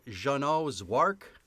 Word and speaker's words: Jeannot Szwarc: Directed by Jeannot Szwarc.